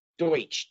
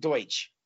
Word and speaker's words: Deutsch: I'm not sure how you pronounce his last name. – Deutsch, Doug Deutsch. – Okay. We should give him a plug. – Great guy, Doug Deutsch.